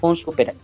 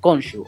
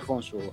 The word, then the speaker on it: Khonsu: Pero, por ejemplo, a Khonsu, que se pronuncia Khonsu, pero los actores de doblaje en español lo llamaron Khonsu, o sea, como es fonéticamente, como es por escrito, pero se pronunciaría Khonsu en el egipcio antiguo.